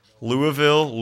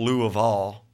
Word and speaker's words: Louisville: There's five ways to say it. Okay, what are the five ways? Louisville, Louisville, Louisville, Louisville, and Louisville, with a silent double L, which is the Spanish...